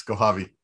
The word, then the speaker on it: Kohavi: Yes, Kohavi.